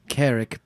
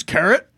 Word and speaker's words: Carrick: Did you just say his name was Carrot? – No, it's Carrick. – With a C. – Oh, with a C. – Yeah, yes. Not Carrot.